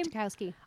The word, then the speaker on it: Ratajkowski: Ratajkowski. – I always call her Emrata because that's what her Instagram handle is. – Ratajkowski.